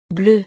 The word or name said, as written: bleue